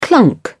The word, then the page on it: clunk: British English